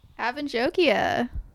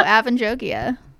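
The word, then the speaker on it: Avan Jogia: Avanjogia. – Wow. – Wow. All right. Well, now we know. Avanjogia.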